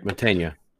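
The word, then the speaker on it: Mantegna: How you say it? Montaigne? – Montaigne.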